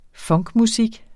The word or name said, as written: funkmusik